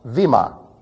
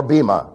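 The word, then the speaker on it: Vima (βήμα): But nevertheless, Vima, or Bima, if you prefer. I misspelled it. This actually says Vema. This should be in Ita. Okay, long E sound.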